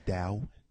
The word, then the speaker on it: Tao: Fan of Tao. – I believe the word is pronounced Dao. – Dao? – Yes. – T-A-O? – With a T?